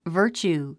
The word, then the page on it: virtue: vəːrtʃuː; バーチュー